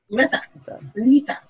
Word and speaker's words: Litha: Litha. Sorry, no T-H. Litha. L-I-T-H-A.